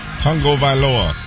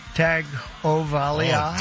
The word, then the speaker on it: Tagovailoa: Tagovailoa. – You got it? Come on. Come on. We believe in you. Come on. – Tua Tagovailoa. – Tagovailoa. – Tua Tagovailoa.